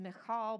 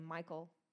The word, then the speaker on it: Michal: Verse 16 says, as the ark of the Lord came into the city of David, it's probably pronounced Michal, but I'll say Michael.